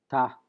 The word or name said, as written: ta